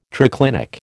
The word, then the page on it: triclinic: American English